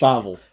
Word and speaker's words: Feivel: We got an American tale, Feivel, or Feivel? How do you say it? – Feivel.